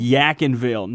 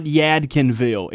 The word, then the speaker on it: Yadkinville: Yakinville, not Yadkinville. – If you pronounce it all the way through, they're going to give you the wrong directions.